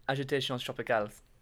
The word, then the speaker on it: Agitations Tropicales: Um, agitations tropicales.